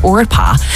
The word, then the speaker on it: Orpah: It's basically the O and the P are switched, pronouncing it Orpah.